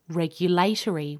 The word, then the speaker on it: regulatory: British and Australian speakers will tend to say regulatory with the stress on the third syllable. American English speakers tend to put the stress on the first syllable, so they say regulatory.